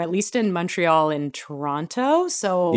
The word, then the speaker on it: Toronto: I'm pronouncing it with the second T, as we were kind of discussing before we started recording this episode. If you're actually from Toronto, then you don't pronounce the second T, but my mom was an English teacher, so I still pronounce it with the second T, despite living here.